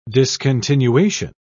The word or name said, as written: discontinuation